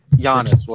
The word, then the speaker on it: Giannis: But freaking Giannis, whatever. – G is silent, like lasagna.